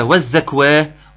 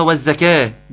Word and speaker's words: والزكاة: إيه ده أنتوا عندكم بص في المصحف كده كويس، أنا شايف والزكاة في واو، دي أنطقها والزكاة ولا والزكوة ولا والزكوه؟ دي أنطقها إيه، الألف بدل واو أنطقها والزكاة. جميل.